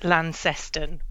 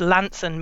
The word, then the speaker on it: Launceston: Well, the BBC refer to it as Launceston, and people that don't know the area might try to pronounce it Launceston, which is how it looks on the page. But if you're proper Cornish, it's Launceston me anson.